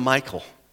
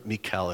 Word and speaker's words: Michal: I'm going to give him Michal, or Michal if you want the other pronunciation.